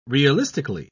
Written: realistically

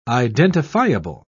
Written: identifiable

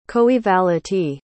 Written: coevality

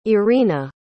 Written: iryna

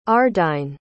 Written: ardyne

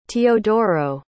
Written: teodoro

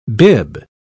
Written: bibb

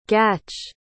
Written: gatch

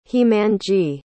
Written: hemangi

